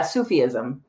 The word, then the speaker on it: Sufism: It's spelled S-U-F-I-S-M. I could not find how to pronounce that word, but that's basically mysticism in Islam. – Yeah. Sufism.